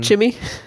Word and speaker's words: Chimay: Is that how it's pronounced? – Yeah. – Oh. – What do you think? – It was like Chimay or something. – Chimmy.